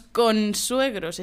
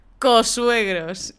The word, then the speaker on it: consuegro: y no decimos consuegros, es que cuesta pronunciarlo, ¿eh? Consuegro. La N delante de la S, qué pereza. Consuegro, la Virgen. Así que no decimos consuegros, decimos cosuegros.